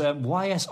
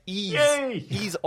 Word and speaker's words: Ys: Yeah, you know, surprisingly, the Dotemu was the bit you got right. Ease Origins. – Origins, not YS then. – Yeah, I wouldn't usually correct you, but I know a lot of Ease fans get very panicky about the pronunciation there.